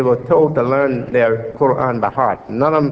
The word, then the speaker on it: Quran: And Quran, some pronounce it Quran. In English, Quran. It's the Quran.